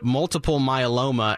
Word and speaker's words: multiple myeloma: He was a frail 84-year-old man with, and correct me if I say this wrong, but multiple myeloma and Parkinson's disease. Right? – Oh, you got it.